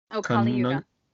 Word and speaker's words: Kali Yuga: How do we square that with, I didn't catch the name, the age of Kali Yuga. – Could you spell that for me? – Kali, K-A-L-I.